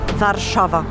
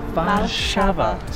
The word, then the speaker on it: Warszawa: How do you say the name of Warsaw in Polish? – Warszawa. – Warszawa. So different to Warsaw.